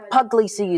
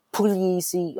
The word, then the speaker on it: Puglisi: Yeah, Puglisi is fine. Puglisi or Puglisi is also fine. Puglisi is the technical pronunciation that none of us really use in the family.